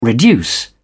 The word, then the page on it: reduce: British English